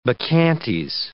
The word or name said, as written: bacchantes